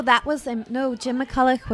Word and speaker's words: McCulloch: McCulloch? Or sometimes, yeah. You can't say Locke. – McCulloch.